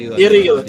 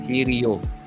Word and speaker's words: Hiryu: How do you say it? – Hiryu. H-I tapos Ryu. No Ken. – Hiryu.